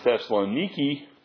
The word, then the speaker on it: Thessaloniki: And if you heard the word Thessaloniki, that is happens to be the way they pronounce that city over there.